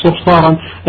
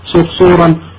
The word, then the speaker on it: صرصور: لكننا في عند العرب صعوبة النطق بفعلون، فأتي بما أتى فيه من نظام فعلون كمثل هذا عصفور، تمام، صرصور، بهلول، هذا هو النطق الصحيح، لا تقل صرصارا لكن قل صرصورا عصفورا هكذا على وزن فعلون.